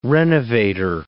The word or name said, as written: renovator